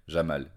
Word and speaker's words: Jamal: Combien de fois j'ai dû moi-même épeler mon prénom de 5 lettres, 3 consonnes et 2 voyelles, Jamal, J-A-M-A-L.